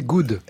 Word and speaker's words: Good: En attendant, je bosse ici et je ne peux que me réjouir de la réouverture de la fondation God Planet pour laquelle... – Non, c'est good. – Oui, mais en français, deux os, ça se prononce.